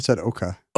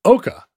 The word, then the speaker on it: Eochaidh: She marries the king of Ireland, whose name is spelled E-O-C-H-A-I-D-H and is pronounced Eoch. Or, yeah. – No, you said Oka. – Oka. It's pronounced Oka. – That sounds right. – Yeah, Oka. – All the DH stuff goes away.